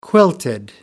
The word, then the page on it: quilted: American English